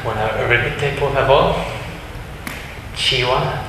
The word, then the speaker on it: Chiwa: Chiwa, C-H-I-W-A. La muerte.